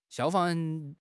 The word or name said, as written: 消防